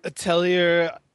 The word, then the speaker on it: Atelier: Atelier.